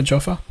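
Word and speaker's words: Jaffe: Is that how you say his name? Jaffa. – Jaffa.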